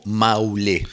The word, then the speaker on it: Marulé: Este disco del 2007 del Grupo Vocal de Sendán, este tema que se llama Marulé, como aparece escrito, pero sí, sin embargo el nombre aparece pronunciado como Maulé. – Maulé. – Maulé. – O Maulé. Sí, sí, también, ojo, en Cuba la R a veces se la fagocita, como que decía, claro.